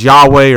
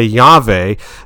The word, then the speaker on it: Yahweh: The actual language, though, it was a YHWH pronounced as Yahweh, or some say Yahweh.